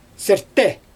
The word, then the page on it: serte: Xyzyl